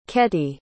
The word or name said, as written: ketty